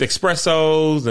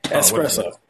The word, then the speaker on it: espresso: This is just a pronunciation thing. Espresso.